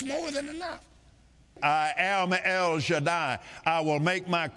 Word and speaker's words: El Shaddai: That's the correct pronunciation. Not Shaddai, not Shaddia. It's El Shaddai. Say it. El Shaddai.